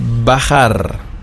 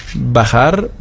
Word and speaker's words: bajar: Acabar, A-C-A-B-A-R, beenden. Bailar, das werden Sie am Wochenende bestimmt auch tun, tanzen. Bailar, B-A-I-L-A-R. Und bajar. Bajar, B-A-J-A-R. Sie erinnern sich an die Aussprache. Wir hatten bei dem J immer das CH. Bach, bajar. Das CH hinten im Rachen.